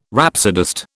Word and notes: rhapsodist: British English

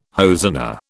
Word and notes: hosanna: British English

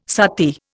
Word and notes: sati: Indian

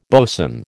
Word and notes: bosun: American English

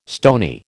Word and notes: stony: American English

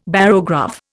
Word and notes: barograph: Indian

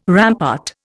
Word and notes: rampart: Indian

